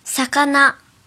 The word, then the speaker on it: 魚: 第二种读作さかな，さかな，さかな，比如钓鱼，さかな釣り，さかな釣り。